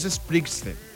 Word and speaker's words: Springsteen: Es Springsteen.